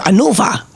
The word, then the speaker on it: Hannover: Scusami, tu che sei stato in Germania, io no, sei stato ad Hannover, come si pronuncia? – Hannover.